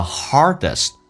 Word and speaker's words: hardest: 因为我们速度比较慢，我们就没有说 Trust this, 没有连音。Trust is the hardest. Hardest 的 D, T 不发音，叫 Hardest.